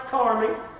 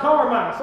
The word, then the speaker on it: Carmi: Yes, for Achan, the son of Carmi, and that is pronounced Carmi. I looked that up in my Hebrew, and the spelling of it is K-A-R-M-E-E. That's Carmi. Everybody all right? It's not Carmine.